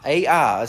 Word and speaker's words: Ai: Ai is how you pronounce that, or how it's spelled.